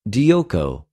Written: διωκω

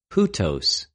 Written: οὑτως

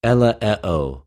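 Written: ἐλεέω

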